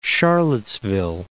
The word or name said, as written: Charlottesville